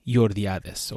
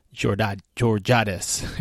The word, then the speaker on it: Jordiades: And I'm excited to present this recording that I did with one of those instructors, Hanshi, or Eighth Dan, Tony Jordiades, or Jordiades, if you can't roll your R's.